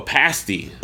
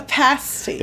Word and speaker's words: pasty: Oh, a pasty. – A pasty. – It looks like it's spelled pasty, but it's actually pasty.